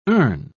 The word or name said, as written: earn